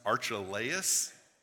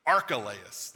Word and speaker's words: Archelaus: But when he heard that, I pronounced his name wrong in the video, it's pronounced Archelaus. Archelaus. There you go.